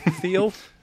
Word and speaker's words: Thiel: Thiel? – Yeah.